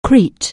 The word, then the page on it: Crete: anglais